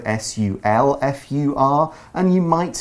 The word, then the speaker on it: sulfur: But sulfur, sulfur, it's spelled it's, it's spelled differently. It's pronounced the same way.